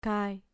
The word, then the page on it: kai: grec